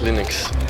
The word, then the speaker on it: Linux: As Linux.